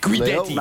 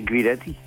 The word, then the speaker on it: Guidetti: Hoe spreek je het precies uit? – Guidetti. – Nou, we hebben aan het begin van het seizoen zelf aan hem gevraagd. Ja. En toen zei hij Guidetti.